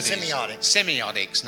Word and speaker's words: semiotics: And but you on this symbiotics, if I've said that right, study. – Semiotics, not yep.